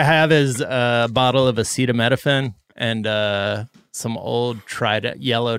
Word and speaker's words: acetaminophen: Wait, that's how you say it? What is it? – Acetaminophen? – I love Kirkland brand. – I thought it was Ace Tammy Nofen.